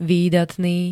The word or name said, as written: výdatný